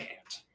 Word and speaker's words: Ant: But most people call me Ant. Yeah. Like the bug.